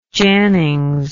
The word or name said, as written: jannings